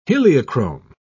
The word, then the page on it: heliochrome: British English